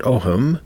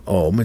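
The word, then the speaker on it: ogham: If you've never heard of the ohm, some people pronounce it ohm, but I just go ohm. It's O-G-H-A-M.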